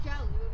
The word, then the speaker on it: Jialu: My name is Jialu. It's spelled J-I-A-L-U. An anagram of my name is Julia. And if you call me Julia, then you're either racist, dyslexic, or autocorrect.